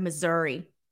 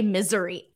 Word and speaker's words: Missouri: I used to say misery, like misery. It took me so long to practice how to say the actual name.